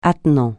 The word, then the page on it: attenant: francais